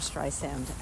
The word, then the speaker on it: Streisand: We do love Barbara Streisand. And yeah. She said soft S.